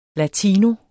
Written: latino

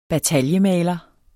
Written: bataljemaler

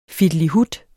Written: fittelihut

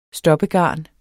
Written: stoppegarn

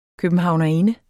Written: københavnerinde

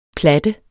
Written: platte

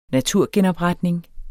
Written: naturgenopretning

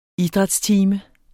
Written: idrætstime

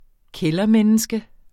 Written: kældermenneske

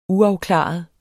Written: uafklaret

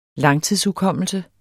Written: langtidshukommelse

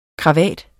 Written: KRAVATTERNES

